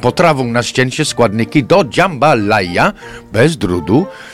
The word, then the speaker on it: jambalaya: Podkarpacka jambalaya. – Tak, i ta jambalaya, bardzo ładnie to wymawiasz, nierozerwalnie wiąże się z, wiesz skąd to jest Louisiana.